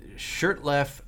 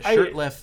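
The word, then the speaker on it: Shurtleff: But basically, so the case is Shirtleff. Is that how you pronounce that? Shirtleff v. Boston. – Yeah, I haven't heard anyone pronounce that out loud. It certainly looks like Shirtleff.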